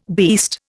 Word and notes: beast: Indian

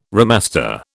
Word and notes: remaster: British English

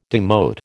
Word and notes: demote: American English

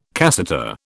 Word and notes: cassata: British English

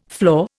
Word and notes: floor: Indian

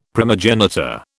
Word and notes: primogenitor: British English

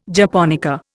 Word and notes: japonica: Indian

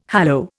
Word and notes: hallow: Indian

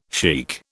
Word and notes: sheikh: British English

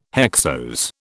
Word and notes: hexose: British English

